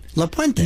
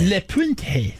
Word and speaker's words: La Puente: La Puente. – La Puente. – I'm not saying it that way. I'm just saying it. – That's what it sounds like to me. James from La Puente.